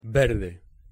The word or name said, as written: verde